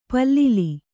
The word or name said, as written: ಪಲ್ಲಿಲಿ